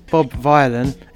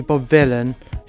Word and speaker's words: Bob Vylan: And that mistake was, I called the band Bob Vylan. It's actually Bob Villain, just how it's spelled.